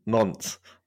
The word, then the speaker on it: Nantes: Not to pick up on your pronunciation, but it does sound like you're saying Nantes. – That's why I was saying I've got to be careful how I say this. How do you say it? – Nantes.